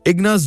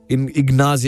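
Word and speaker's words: Ignaz: इग्नस जो थे, इग्नास या इग्नस, दोनों अलग अलग प्रोनाउंसिएशन बोला था, इग्नास वैसे मैंने मोस्टली सुना है, सो IGNNAZ, इनके बारे में क्यों मैं इतना जोर दे रहा हूं पता लगेगा।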